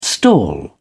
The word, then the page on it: stall: British English